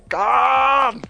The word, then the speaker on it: Cannes: perdón, el abuelo Kraken me corrige, es el Festival de Cannes. Bueno, es que yo no pronuncio bien, así de Cannes. – Tiene la referencia. – Festival de Cannes. Así suena mejor, ¿verdad? – Sí.